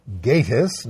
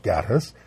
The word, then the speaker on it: Gatiss: He's Mark Gatiss, not Gattis.